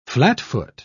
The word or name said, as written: flatfoot